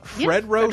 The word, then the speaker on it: Fredro: Fredro yep, Starr and Jill Scott from the Save the Last Dance soundtrack.